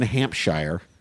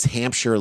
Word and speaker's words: Hampshire: According to police in Hampshire, I'm pronouncing that horribly. – Hampshire.